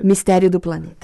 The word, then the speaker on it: Mistério do Planeta: Is that, did I say that right? – Misterio do Planeta. – Misterio do Planeta. Okay.